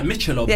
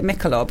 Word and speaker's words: Michelob: That looks like Michelob. – Yeah, how do you say that? Am I just saying it Mikkelob? Michelob.